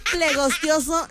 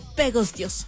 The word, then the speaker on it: pegostioso: La palabra plegostioso no existe en el idioma español y es una deformación de la original.